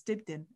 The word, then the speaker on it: Dibdin: It's my surname that trips people up because obviously it's spelled Dibdin, so it's Dibdin, Ellen Dibdin.